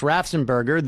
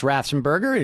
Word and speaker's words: Raffensperger: And now what they've done is they have stripped whoever is functioning as Raffensperger. At this point, it's Raffensperger, but the Secretary... – Raffensperger, you said it three times, so I had to correct you.